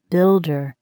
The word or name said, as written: builder